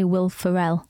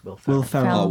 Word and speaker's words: Will Ferrell: Yes, there is, I always say it wrong. I say Will Ferrell. But it's not. – It's Will Ferrell.